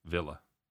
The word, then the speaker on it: Villa: Max says we should be staying Aston Villa. Villa. It's the English pronunciation. – I like to say Villa.